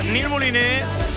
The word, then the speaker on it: Nil Moliné: Bueno, es Nil Moliné. Como se pronuncia en Cataluña, el apellido Nil Moliné estará el próximo viernes con los 40 en las fiestas de la Marseille, las fiestas de Barcelona.